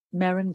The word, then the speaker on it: Merryn: Merryn Glover.